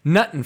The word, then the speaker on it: nuttin': And it's like a little kid singing about how he got nothing for Christmas. But it's not nothing for Christmas. It's nuttin' for Christmas. And it's spelled N-U-T-T-I-N apostrophe for Christmas.